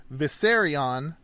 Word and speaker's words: Vissarion: Viserion, not spelled like the dragon from Game of Thrones, but probably pronounced the same. V-I-S-S-A-R-I-O-N.